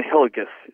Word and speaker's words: Hillegas: Ryan Hillegas is the correct pronunciation. So Ryan, R-Y-A-N, and then Hillegas, H-I-L-L-E-G-A-S.